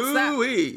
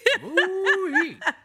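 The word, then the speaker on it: Moooi: It's M-triple-O-I. – M-triple-O-I. – M-triple-O-I. – Yes, pronounce Woo-wee. – That. – Woo-wee.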